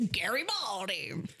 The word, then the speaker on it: Garibaldi: And that's how they say it. I was trying to pronounce it a fancier way. And then they're like, nope, it's Garibaldi.